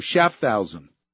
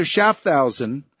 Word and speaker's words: Schaffhausen: How do you pronounce this? Schaffhausen, huh? Say it like Mathausen? Oh, Schaffhausen, like Mathausen. I remember Mathausen, the concentration camp. Professor Schaffhausen has remarked that these will no doubt be exterminated.